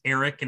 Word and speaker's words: Erek: His name is Eric, and it's spelled E-R-E-K, which I thought was a very unique, cool way to spell Eric.